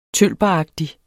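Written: tølperagtig

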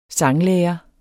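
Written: sanglærer

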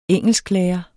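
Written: engelsklærere

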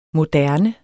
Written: moderne

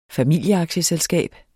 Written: familieaktieselskab

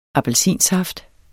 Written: appelsinsaft

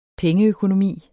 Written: pengeøkonomi

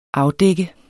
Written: afdække

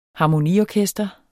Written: harmoniorkester

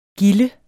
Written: gilde

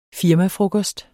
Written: firmafrokost

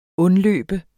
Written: undløbe

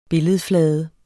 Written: billedflade

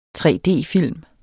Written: 3D-film

